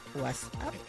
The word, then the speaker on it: WhatsApp: WhatsApp.